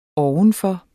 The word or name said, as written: ovenfor